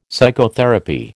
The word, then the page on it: psychotherapy: American English